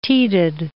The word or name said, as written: teated